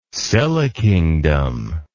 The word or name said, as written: Silla kingdom